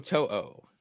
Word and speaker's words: To'o: Um, I cannot say his name, but I like Henry. To'o, um, To'o. Yes, I mean you, you, got a linebacker that's intelligent.